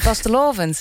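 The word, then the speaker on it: vastelaovend: Oftewel vastelavond, zeg ik dat goed? Die komt uit Maastricht. – Nee, het is vastelavond.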